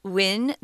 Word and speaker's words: Nguyen: And our authors uh, here, his name is Viet Thanh Nguyen. That's the English pronunciation, guys.